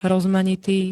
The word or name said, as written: rozmanitý